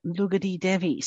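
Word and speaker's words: Lugdi Devi: No, it's actually spelled like Lugdi, but when I actually, because I try and be very respectful of people's cultures and I like to pronounce the words as correctly as I can. So when I actually looked it up, it is actually said Lugati Devi.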